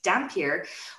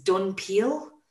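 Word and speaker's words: dhampir: And they also, yeah, Dampier was mispronounced as Dunpeel.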